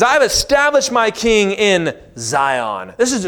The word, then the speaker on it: Zion: This actually pronounced with a TS at the beginning Zion. Zion. We don't pronounce many Hebrews words right.